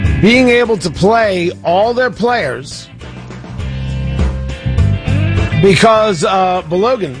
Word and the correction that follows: Balogun: Tony, it is Balogun.